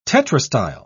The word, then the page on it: tetrastyle: British English